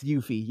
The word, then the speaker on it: Yuffie: Will, it's Yuffie.